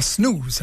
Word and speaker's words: snooze: Tu l'as bien prononcé, toi, snooze.